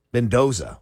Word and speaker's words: Mendoza: And this is Menendez. Is that how you say his name? Mendoza. What is wrong with me? Oh, my God. Fernando Mendoza. As I'm looking at it, I'm like, that's not how you say that.